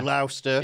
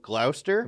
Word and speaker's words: Gloucester: Where is Gloucester? – In Wales. Yeah. – Or do you prefer Gloucester? – Gloucester. – You can't pronounce the C.